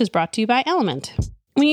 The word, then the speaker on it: LMNT: Enter Element, that's spelled L-M-N-T, a tasty electrolyte mix with everything you need and nothing you don't.